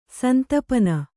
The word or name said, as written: ಸಂತಪನ